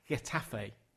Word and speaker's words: Getafe: I saw Atletico Madrid, and then I saw a game at Getafe.